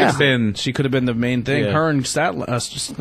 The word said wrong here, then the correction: Statlander: How do you say it? – Chris Statlander.